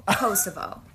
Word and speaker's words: Kosovo: Kosovo. – Kosovo. Kosovo. – Kosovo. – There's no T, honey. It's Kosovo.